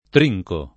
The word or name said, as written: trincare